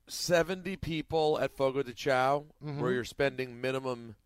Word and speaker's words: Fogo de Chão: That's how you pronounce that, right? – That's right, Fogo de Chão.